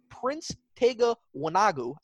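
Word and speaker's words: Prince Tega Wanogho: Prince Tega Wanagu. I probably butchered his name too. – But no, you didn't. That was good.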